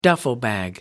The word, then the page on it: duffel bag: American English